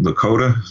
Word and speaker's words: Lakota: An undercover cop forms an alliance with a Native American to help him hunt down the criminal who stole an ancient Lakota.